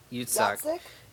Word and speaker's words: Yitzhak: Yitzhak? – Yitzhak. Yitzhak. – Yitzhak, yeah. – Yitzhak. So, Yitzhak, and then. – I think. – And then lets yeah. Yitzhak become Hedwig.